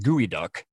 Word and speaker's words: geoduck: And then the other kind of common culinary clams, another weird spelling, it looks like geoduck, but it's actually pronounced gooey duck, which doesn't sound appetizing, gooey duck.